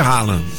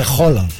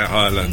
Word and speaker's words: Haaland: Mas você vê que os narradores de futebol continuam chamando ele de Haaland. – Por quê? Porque me disseram que como o nome realmente, quando você o pronuncia direito, fica complicado em português, porque é Roland. – É Roland.